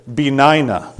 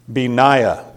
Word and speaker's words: Benaiah: His name, I had to look up how to say it, Benaiah. is how that fancy mouth book says you're supposed to pronounce it. Benaiah.